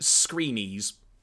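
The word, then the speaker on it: Skrenes: Apparently, the last name is pronounced Screenies.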